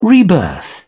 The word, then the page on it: rebirth: British English